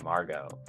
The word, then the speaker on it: Margot: Um, Co- the T is silent there, Margot.